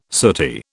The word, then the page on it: sooty: British English